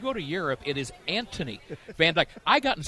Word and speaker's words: Anthony: And if you go to Europe, his name is pronounced Anthony. You don't say the H.